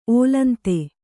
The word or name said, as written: ಓಲಂತೆ